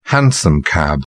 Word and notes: hansom cab: British English

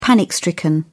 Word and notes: panic-stricken: British English

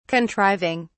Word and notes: contriving: British English

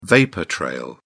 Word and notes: vapour-trail: British English

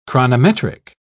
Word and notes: chronometric: British English

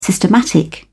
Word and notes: systematic: British English

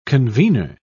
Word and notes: convener: British English

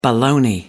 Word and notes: boloney: American English

